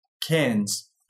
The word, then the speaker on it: Cairns: And we've asked him to do something extremely important and that is correctly pronounce a word that we incorrectly pronounced in a previous episode. Take it away. Cairns. Thank you.